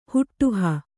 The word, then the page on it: ಹಡುಹು: Kannada